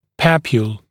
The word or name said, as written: papule